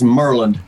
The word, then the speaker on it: Maryland: If you're from here, they make it just two syllables. It's Maryland.